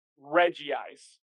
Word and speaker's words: Regice: Yeah, like they told me the correct Pokemon pronunciation is Regice. And I said, where's the second vowel sound coming from? Because on the Pokemon before, they're like, oh, you're pronounced. And he's like, well, like, no, it changes because he's a Reggie. So it's Regice.